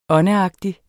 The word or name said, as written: åndeagtig